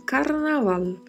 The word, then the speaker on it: carnaval: Carnaval que é o nome da festividade e se pronuncia desta maneira em espanhol, carnaval, com o R vibrato, o V com leve som de B e o L com o som da língua entre os dentes.